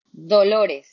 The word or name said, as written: Dolores